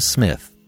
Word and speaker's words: Psmith: I hope you have enjoyed this unabridged production of Leave It to Smith, The P is Silent, Part 1 of 10 by P.G.